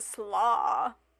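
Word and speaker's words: Sluagh: So that's the Vikras, and then this one is called the Slaw, which sounds kind of coleslaw fairies. – Coleslaw, but it's spelled like S-L-U-A-H. Ooh. A-G-H. So it's not like coleslaw, but different.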